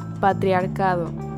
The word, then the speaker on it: patriarcado: Next one, how do you say patriarchy? – I think we see this one before. Patriarchy? – Patriarchy. – Patria? – No. – I don't know. – Patriarcado. – Patriarcado. – Yes. – Patriarchy. – Yes. – Patriarcado. – Patriarcado. – All right, that's a mouthful. Patriarcado.